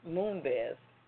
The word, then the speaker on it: Moonves: Media company CBS Corp's board is in talks with Chief Executive Officer Les Moonves to negotiate his exit.